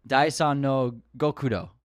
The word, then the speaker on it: Daisan no Gokudo: I'm going to try to pronounce this, Daisan no Gokudo. – Not bad. Sounds pretty good.